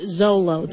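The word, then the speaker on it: Xolo: Yeah, but it's pronounced Zolo.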